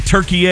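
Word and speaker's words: Türkiye: in action Thursday night against Turkey, or Turkey, if you prefer. I don't know. It's weird pronouncing it that way, but apparently that's correct.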